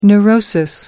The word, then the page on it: neurosis: American English